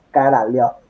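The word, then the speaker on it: Korolev: Coriolov eh, viene de Coriolisis, ¿no? ¿verdad? No. Eh, la, pronunciación más correcta es Karaliov.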